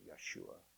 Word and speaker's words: Yeshua: And they pronounce it Yeshua.